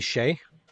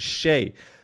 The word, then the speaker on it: Skjei: But then you've got a nice rounded out with like a Brady, pronounce his last name for me, S-K-J-E-I. How do you pronounce that? – Brady Shea. – Shea. That's pronounced Shea. Well done.